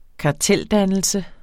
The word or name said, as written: kartel